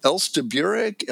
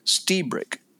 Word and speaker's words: Lstiburek: it's it's spelled L S T I B U R E K, and it's pronounced Stebrick. That's how he pronounces it.